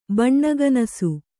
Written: ಬಣ್ಣಗನಸು

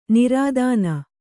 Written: ನಿರ್ಧನ

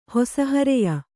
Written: ಹೊಸಹರೆಯ

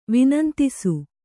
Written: ವಿನಂತಿಸು

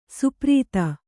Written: ಸ್ಪರ್ಧಿ

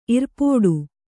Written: ಇರ್ಪೋಡು

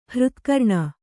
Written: ಹೃತ್ಕರ್ಣ

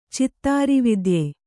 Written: ಚಿತ್ತಾರಿವಿದ್ಯೆ

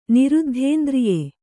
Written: ನಿರುದ್ಧೇಂದ್ರಿಯೆ

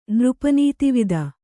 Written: ನೃಪನೀತಿವಿದ

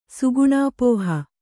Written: ಸುಗುಣಾಪೋಹ

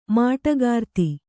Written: ಮಾಟಗಾರ್ತಿ

